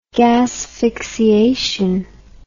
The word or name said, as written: gasphyxiation